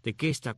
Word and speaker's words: Tequesta: pero es tequesta, tequesta con Q, T-E-Q-U-E-S-T-A, los tequesta,